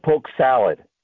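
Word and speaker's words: poke sallet: It sounds like poke salad when they say it, but it's not spelled that way. It's spelled with a T, not a D.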